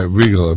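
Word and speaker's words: Riegle: His name was Don, and it's R-I-E-G-L-E. – Right, Regal. Basically, Regal.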